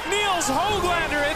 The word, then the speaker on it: Nils Höglander: Can I please get a pronunciation verification on that? – Nils Höglander, it's time to hand out the sweets.